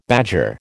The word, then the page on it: badger: American English